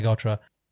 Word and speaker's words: gotra: Like the Trandoshans have their gotra and the Aqualish have their gotra. The it's an actual it's, it's it's a Sanskrit word actually, gotra, correct pronunciation, which means clan or you know the blood clan or whatever.